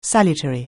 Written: salutary

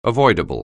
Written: avoidable

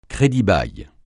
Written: crédit-bail

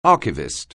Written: archivist

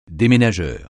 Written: déménageur

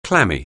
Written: clammy

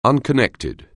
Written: unconnected

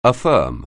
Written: affirm

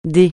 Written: dé

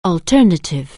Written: alternative

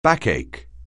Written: backache

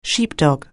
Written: sheepdog

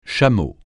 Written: chameau

